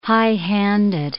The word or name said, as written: high-handed